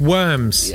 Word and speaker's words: Worms: Happy death day to Minna of Worms, a wealthy Jewish woman who lived in Worms in the Worms, it's pronounced Worms.